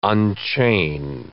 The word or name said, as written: unchain